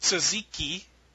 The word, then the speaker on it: tzatziki: There's a tandoori spiced yogurt, and there's a say it right tzatziki, yes.